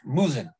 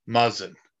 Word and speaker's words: Muzzin: As he's going to take us into the first bit of news, and that's, I'm going to butcher the guy's name, and even though it's a simple one, Jake Muzzin. – Muzzin. – I told you I was going to butcher it.